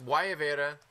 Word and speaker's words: Guayabera: I said it wrong in the intro of the show. I said Guayabera. Very white boy of me. – Mm. I thought it was like guava something. So no, I completely messed it up. – It's Guayabera. That's how you pronounce it.